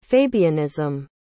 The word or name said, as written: fabianism